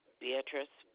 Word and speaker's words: Beatrice: Beatrice Juan Rostro. – That's B-E-A-T-R-I-C-E? – Yes.